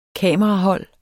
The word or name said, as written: kamerahold